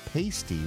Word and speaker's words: pasty: And Diane points out that pasty with a long A is what strippers wear.